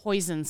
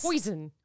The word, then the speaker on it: Hoisin: How do you pronounce this one? – Hoisin sauce. – Hoisin.